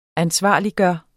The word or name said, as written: ansvarliggøre